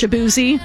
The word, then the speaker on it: Shaboozey: Those are both really, really, like, I can just read those songs and even know, like, the fact that I know that that is pronounced Shaboozey and it's a bar song, that song was super popular.